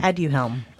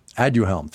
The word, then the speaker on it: Aduhelm: Aduhelm, I believe. – It's not coming. Aduhelm, thank you very much.